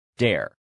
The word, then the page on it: dare: male